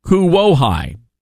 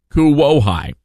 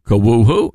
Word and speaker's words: Kuwohi: Moving forward, Clingman's Dome will be known as the Cherokee word Kuwohi, K-U-W-O-H-I, Kuwohi. Kuwohi is the correct pronunciation. Kuwohu? No, Kuwohi.